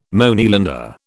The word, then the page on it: Moneylender: British English